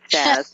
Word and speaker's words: Shaz: Am I pronouncing your name like with my New York accent? Even though I'm in Seattle, I have a little bit of that. I'm probably mispronouncing your name, but I do that really well, though. – No, it's fine. It's Shaz.